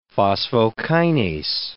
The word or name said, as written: phosphokinase